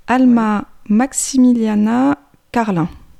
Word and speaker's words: Alma Maximiliana Karlin: Alma Maximiliana Karlin. Je prononce bien ? – Oui, à la française, c'est ça.